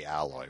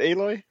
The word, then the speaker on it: Aloy: And Aloy, I keep wanting to say Alloy, which is why I'll stutter a little bit. – They pronounce it Aloy? Is that how it's pronounced? – Exactly. Yeah, yeah. They don't use the second L, which is why.